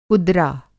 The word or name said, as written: कुदरा